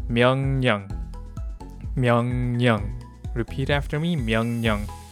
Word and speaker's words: 명령: By the way, 명령 is spelled out with a 르, but it sounds like a 니은 because it follows the sound 니은. So 명령 instead of 명령.